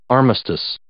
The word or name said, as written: armistice